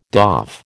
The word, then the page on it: doff: American English